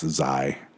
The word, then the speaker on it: Xi: And is it Xi? I'm pretty sure it's pronounced Xi.